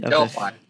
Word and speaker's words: Delphi: So he said, I've never got the newer version, the Delphi. I don't know if I said that right. – Delphi.